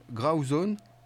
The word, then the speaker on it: Grauzone: Tu me dis si je prononce bien. Je crois que le groupe s'appelle Grauzone. – Ouais.